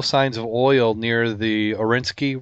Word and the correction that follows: Oriskany: wreck. And I'm probably saying that wrong. I looked it up several times. The USS Oriskany. – Oriskany. Yeah, so you can join me in mispronouncing it.